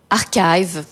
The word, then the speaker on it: Archive: Alors je pense qu'on prononce Archive ou Archive, je ne sais pas trop. Comment tu dis toi? – Archive. Archive à la française.